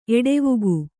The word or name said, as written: ಎಡೆವುಗು